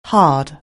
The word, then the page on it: hard: anglais